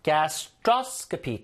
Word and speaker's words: gastroscopy: 그래서 get a gastroscopy. 자, gastro 이게 위라는 뜻이거든요. 그래서 읽을 때 gastroscopy 강세가 되게 중요해요. 긴 단어일수록 무조건 어디다 힘을 주는지가 바르게 읽는 어떤 가장 중요한 그 이슈거든요. Get a gastroscopy.